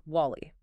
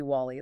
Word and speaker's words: WALL-E: The title of the movie is WALL-E. But Big Fatty absolutely refuses to pronounce it correctly. He insists repeatedly on calling the movie WALL-E, like the old-fashioned name.